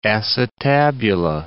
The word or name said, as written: acetabula